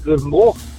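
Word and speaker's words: Grevenbroich: Aus Grevenbruch. – Grevenbruch, das ist, da falle ich immer drauf rein, wird aber mit OI geschrieben, oder? – Genau. – Aber ohne I ausgesprochen.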